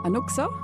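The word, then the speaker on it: Anuk$a: This song is by Dina Anuka, Anuksa. So the way you spell it, D-E-N-A, and Anuksa is A-N-U-K dollar sign A.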